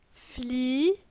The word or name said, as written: Fli